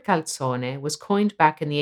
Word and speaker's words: calzone: The authentic Italian pronunciation of calzone differs from the English in two main ways. First, the Z is pronounced ts. – Second, the word final e is always pronounced. Have a listen to the correct pronunciation.